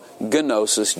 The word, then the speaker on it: gnosis: So, gnosis, G-N. But the G is silent. So it's just gnosis.